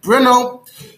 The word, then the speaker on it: Brno: Today it's pronounced something like Brno.